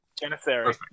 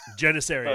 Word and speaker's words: Janissary: That is a Maccabian Janissaris. I think I said that right. – Yes. – Janissary. – Janissary. – Janissary? All right. I think I did that right.